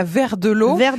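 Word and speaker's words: Verdelot: qui est donc à vert de l'eau ça s'écrit V-E-R-D-E-L-O-T. E e L-O-T.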